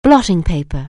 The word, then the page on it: blotting paper: anglais